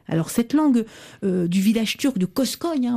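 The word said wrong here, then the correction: Kuşköy: au bord Koshkoy, de la... – Ouais. – Koshkoy, on dit.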